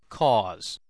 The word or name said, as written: cause